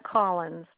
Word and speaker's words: Collins: Okay, the first name is Annette, A-N-N-E-T-T-E, and the last name is Collins, C-O-L-L-I-N-S.